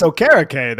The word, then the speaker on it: Okereke: I believe it's Okereke, though. You're still not saying it correctly, Ed.